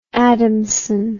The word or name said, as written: Adamson